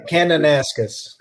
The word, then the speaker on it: Kananaskis: Casco, Casca, Kanakaskus, or I forget how that's pronounced. Is actually Kananaskis. – Kananaskis.